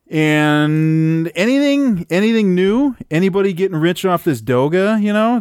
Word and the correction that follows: Doge: It's Doge.